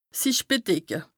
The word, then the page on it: sich bedecke: Bas-Rhin; Strasbourg